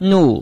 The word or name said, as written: nu4